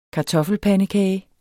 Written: kartoffelpandekage

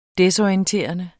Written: desorienterende

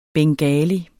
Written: bengali

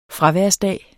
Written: fraværsdag

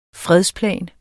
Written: fredsplan

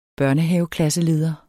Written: børnehaveklasseleder

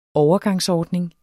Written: overgangsordning

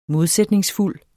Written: modsætningsfuld

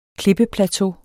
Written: klippeplateau

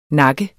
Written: nakke

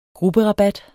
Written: grupperabat

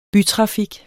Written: bytrafik

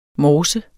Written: morse

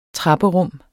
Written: trapperum

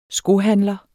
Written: skohandler

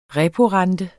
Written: reporente

